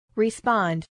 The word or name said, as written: respond